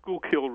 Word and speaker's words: Schuylkill: You're from Philadelphia, and it's at the, and I can never pronounce the name of the river, Schuylkill River? I'd never know. – Schuylkill River.